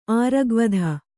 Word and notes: ಆರಗ್ವಧ: āragvadha

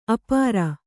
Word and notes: ಆಪೂರ: āpūra

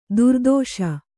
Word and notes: ದುರ್ದೆಶೆ: kannada

